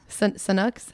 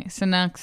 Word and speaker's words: Sanuk: Like the brand is Sanuk. Snooks. Like we kind of call them both.